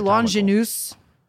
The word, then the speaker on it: Longinus: So I'm going to say Longinus because that's how they say it in the show.